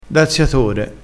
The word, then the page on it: daziatore: dialettale